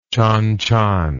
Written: chan chan

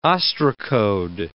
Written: ostracode

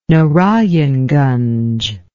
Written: narayanganj

